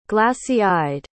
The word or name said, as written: glassy-eyed